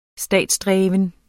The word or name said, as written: statsdreven